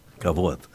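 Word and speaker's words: Кабод (כבוד): Я ударение не могу точно сказать. Кабод, правильно? – Кабод.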